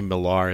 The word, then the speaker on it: Millar: If you don't know who Mark Millar is, the reason why I say Millar is because his name is spelled M-I-L-L-A-R, but I think it's just Miller. – Yeah. – Oh, no, it's Mark Millar.